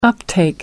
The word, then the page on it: uptake: American English